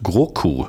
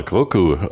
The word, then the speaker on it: Grogu: Dann reden wir über Palästina 36 und über einen neuen Star Wars Film, der Mandalorian und Grogu. – Grogu! – Schauen wir mal, Grogu. Ob nicht Grogu, sondern Grogu.